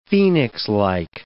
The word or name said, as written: phoenixlike